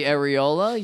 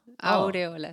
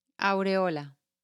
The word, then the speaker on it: aureola: And you want to know the areola? Yeah. – Yeah. – Aureola. – Areola? – Aureola.